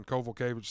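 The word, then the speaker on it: Kowalkiewicz: Tell me how to say her name again. It's Kovalkiewicz. – Kovalkiewicz. – Kovalkiewicz. – The way you say it, there's a V in there, but – you know, I don't know. – The way I say it is I don't look at her last name and I look at you and say, how do you say it? – Kovalkiewicz. – Kovalkiewicz, that's it.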